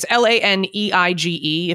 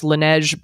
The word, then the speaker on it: Laneige: It's Laneige. If it was French, it's Laneige, but I'm not sure how people, you know, say it here. – I've always seen it and I never knew how to say it, but I know the brand. – Most likely. – I've used it and I like it. – Americans probably say Laneige, I guess.